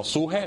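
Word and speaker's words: suje (ψυχή): De hecho, la palabra sentir allí es una palabra en griego que significa, o que se pronuncia como suje,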